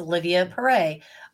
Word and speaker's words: Olivia Perret: Today my guest is Olivia Perret.